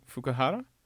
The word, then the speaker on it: Fukuhara: I think it's Fukuhara. – That sounds right.